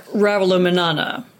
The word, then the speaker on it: Ravalomanana: Ravalomanana.